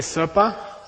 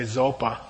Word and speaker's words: sopa: Say sopa. – Sopa. – This is the word they mispronounce, the poor lamas in the world whose name is supa. They say zopa, okay, or something like that. It's sopa.